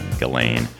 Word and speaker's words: Ghislaine: I've already mispronounced it on my previous take, Ghislaine.